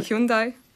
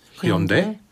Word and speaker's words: Hyundai: Hyundai izrunā kā Hyundai. – Hyundai? – Hyundai.